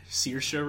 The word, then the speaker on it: Saoirse: Also, I know how to pronounce Miss Ronan now. It's Saoirse Ronan. It's Gaelic.